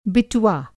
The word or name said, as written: बिटुआ